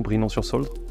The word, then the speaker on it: Brinon-sur-Sauldre: Sidi, euh, est-ce que j'ai prononcé correctement brinon sur Soldre Oui, très bien.